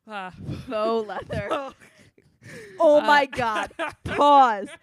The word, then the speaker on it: faux: So the word faux fur or faux leather, F-A-U-X, which she was pronouncing fox. I would like to know how you thought foxes made leather. – No, fox, like, just, I was sounding it out. Fox. F-A-U-X.